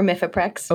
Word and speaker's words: Mifeprex: Now, one of them, and I forgive me if I say this incorrectly, is it Mifeprex? – Yes, mifepristone or Mifeprex.